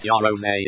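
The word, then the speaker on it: Yaroze: This issue would have been written in late 1996, pre-launch of the Western Netyaros release, and using the English translation Yaros, pronounced Yarose in the next issue, February 26, Next Generation printed the following. We say Yaros, Sony says Yaros.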